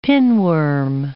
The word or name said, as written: pinworm